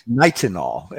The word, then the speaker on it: Nitinol: Nitinol. Is that correct? – Nitinol.